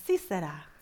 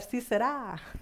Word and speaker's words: Sisera: Bueno, estuve buscando la pronunciación y nomás decía, sí será. Dije, uy, esas me la voy a aventar, sí será.